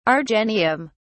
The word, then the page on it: argenteum: British English